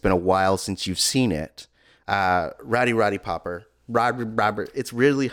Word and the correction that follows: Piper: Rowdy Roddy Piper.